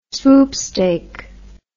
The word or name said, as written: swoopstake